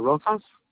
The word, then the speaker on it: Rosas: Rosas. – Spell that for me. – R-O-S-A-S.